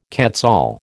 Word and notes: quetzal: American English

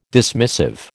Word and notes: dismissive: American English

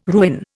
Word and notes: ruin: Indian

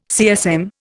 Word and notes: CSM: Indian

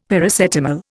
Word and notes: Paracetamol: Indian

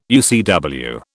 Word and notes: UCW: British English

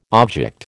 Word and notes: object: American English

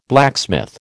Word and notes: blacksmith: American English

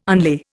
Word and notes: unlay: Indian

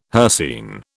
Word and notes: hircine: British English

